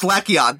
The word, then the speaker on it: Glaceon: And number five, Glaceon. – No, it's Glaceon. That's your silent.